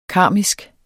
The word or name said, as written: karmisk